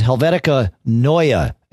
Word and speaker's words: Helvetica Neue: There is a new font, and it is Helvetica Neue is how it is pronounced. N-E-U-E is...